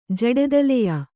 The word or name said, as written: ಜಡೆದಲೆಯ